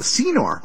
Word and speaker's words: Senor: And so she had a special that was on Senor. Oh, thank you. Got Ricky Champagne behind the board there telling me it's pronounced Senor.